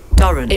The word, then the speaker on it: Durrant: And my surname is Durrant. – Durrant. – It rhymes with current.